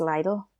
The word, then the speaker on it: Lidl: I know for little, I say little. But I say little. Apparently, it's Lidl. – Lidl? – Apparently. That's what I've heard. – I say little. – Everyone's saying it wrong, apparently.